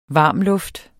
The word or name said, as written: varmluft